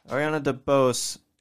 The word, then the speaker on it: Ariana DeBose: We have Ariana DeBose. Am I saying that right? – Yeah. – Ariana DeBose in West Side Story.